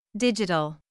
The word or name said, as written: digital